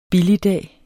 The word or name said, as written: billigdag